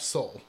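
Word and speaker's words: Sole: But how do you say his last name? Is it Soul or Soulette? – Soul. – Okay, Soul. He was inspired to make the film after seeing Don't Look Now, mm-hmm.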